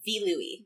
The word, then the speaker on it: Vilui: How do you pronounce it? Bido Yui? – Vilui. – Vilui? Yeah. My Vilui. Oh, okay. Like, okay. In the scanlation then they did a B instead of a V. That makes sense.